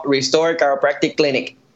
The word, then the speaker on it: Restore Chiropractic Clinic: Bueno, eh, hacemos un poquito el, el mimic de como si tuviéramos acento americano y decimos Restore Chiropractic Clinic.